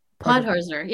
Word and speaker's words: Podhorzer: You had a great interview, Dahlia, with Michael, whose name I can't pronounce. So if you put a hurt. – Podhorzer. Yeah.